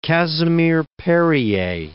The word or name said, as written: Casimir-Perier